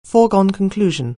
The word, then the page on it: foregone conclusion: anglais